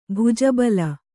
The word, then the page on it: ಭುಜಬಲ: kannada; bhuja bala